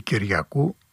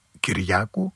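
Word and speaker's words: Kiriakou: Τζον Κυριάκου ή Κυριακού, αλλά ο ίδιος όταν αναφέρεται στο όνομά του το προφέρει και το τονίζει Κυριάκου, είναι από οικογένεια Ελλήνων της Αμερικής.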